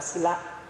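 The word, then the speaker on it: sīla: In Pali we call sila. – Và tiếng Pali thì đọc là sila.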